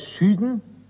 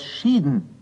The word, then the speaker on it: schütten: Das Wort schütten kann man ebenso oft als schüden wie als schieden hören.